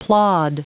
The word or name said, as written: plod